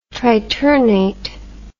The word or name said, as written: triternate